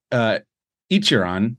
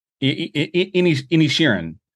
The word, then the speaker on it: Inisherin: Fifth movie is Banshees of Inisherin. I think I already said it wrong. It's not Inisherin. Inisherin. The Banshees of Inisherin.